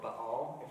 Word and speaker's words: Baal: a god called Baal, or Baal, if you want to actually pronounce it correctly.